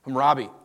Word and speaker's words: Hammurabi: I totally butchered that name. Hammurabi, there we go.